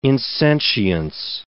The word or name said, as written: insentience